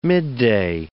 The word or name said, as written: midday